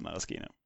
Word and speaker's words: maraschino: Ja, men då har vi väl en gång för alla rätt ut det, att det heter maraschino.